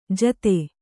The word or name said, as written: ಜೋತಿ